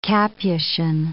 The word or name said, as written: capuchin